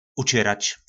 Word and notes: Učyrač: Po našimu

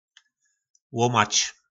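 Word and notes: Łómač: nářečí Po našimu

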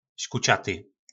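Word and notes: Skucaty: nářečí Po našimu

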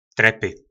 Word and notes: Trepy: Po našimu

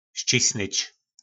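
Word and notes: Scisnyc: nářečí Po našimu

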